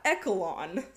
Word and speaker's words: echelon: called echoline swimming. I don't think I'm saying that correctly. It's spelled E-C-H-E-L-O-N. How do you pronounce that? – I would say echelon.